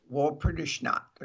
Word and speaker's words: Walpurgisnacht: Walpurgisnacht or something.